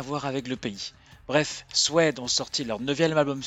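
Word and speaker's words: Suede: Après European Ghost, j'ai eu envie de passer un titre du nouvel album de Suède, qui se prononce Suède en anglais,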